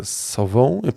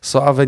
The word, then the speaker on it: Sauvé: Tylko ani krema, ani niestety ta Sauvé, przepraszam, źle wymawiam. – Sauvé nie jest.